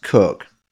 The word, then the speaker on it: Coke: The word, the surname Cook, C-O-K-E, looks like it's pronounced Coke, but is often pronounced Cook.